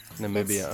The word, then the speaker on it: Namibia: It's in Namib- Namibia. – Namibia. – It's okay, cool. Namibia. – Ooh, it. yeah. – Have you ever been, to Namibia? I have not. Africa. – Namibia.